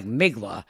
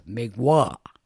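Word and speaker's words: Mgła: And now it's spelled like MGLA, so it sounds like Migla, but actually it's Migwa.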